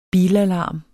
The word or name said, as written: bilalarm